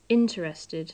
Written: interested

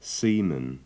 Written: seaman

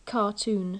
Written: cartoon

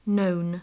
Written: known

